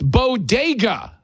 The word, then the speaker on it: bodega: Bogadas in the Bronx, except there's only one small problem. It's pronounced bodega.